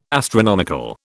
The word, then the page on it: astronomical: British English